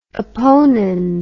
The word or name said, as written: opponens